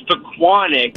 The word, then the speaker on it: Pequannock: So how do you pronounce the town you're in? – Tommy, it's Pequannock, but even funnier, Pequannock, but I grew up in Secaucus.